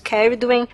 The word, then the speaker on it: Ceridwen: O deus mais antigo celta é o Cernunnos, que é ali, ali mais do país de Gales, junto com Ceridwen também. Escreve Cernunnos e Ceridwen, tá? Lê-se Cernunnos, Ceridwen.